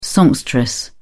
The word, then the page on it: songstress: British English